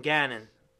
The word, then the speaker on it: Gannon: His name rhymes with cannon. That's something big on defense. You want a guy who's a cannon. You think about that, you know, hey, whoa, watch out. Here's Jonathan Gannon, the cannon.